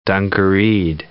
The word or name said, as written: dungareed